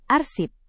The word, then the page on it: arsip: indonesian